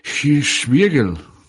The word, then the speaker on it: Hirschbiegel: Es que de verdad que a mí no me, no me sonaba para nada, pero como para que te suene Hirschberger, ¿cómo era? Hirschberger. Ah, bueno, pues es. O sea, es, como, o como tú lo pronuncias mejor, que es Oliver Hirschbiegel.